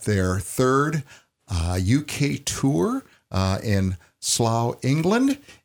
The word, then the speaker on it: Slough: Yeah, Slough, or something like that. – Yeah. – It rhymes with plow, Slough.